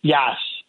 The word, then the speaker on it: Yass: Yass. Like, have you ever seen like the Yass Queen memes that are popular online? It's just like that. Jeffrey Yass. – So tell me about Jeffrey Yass.